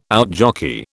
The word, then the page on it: outjockey: British English